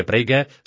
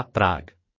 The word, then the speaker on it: Prague: I když dnes už zdaleka najde o české město a jeho název se vyslovuje Prague, stále se jmenuje stejně jako česká Praha, Prague.